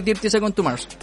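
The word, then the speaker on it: Thirty Seconds to Mars: está muy pegado con el último álbum de Dirty Second to Mars. Dirty Second, Second to Mars, sí, lo dije bien.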